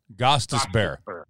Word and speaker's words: Gostisbehere: Gostisbehere. – Gostisbehere.